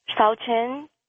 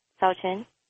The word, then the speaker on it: Shaochun: Tao Chen. Tao Chen. – Okay. – Can you spell that? – S-H-A-O-C-H-U-N.